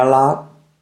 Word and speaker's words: lot: Do you come here a lot? Do you come here a lot? Saltamos la H de here si quieres. Do you come here a lot? La T de lot casi no se dice.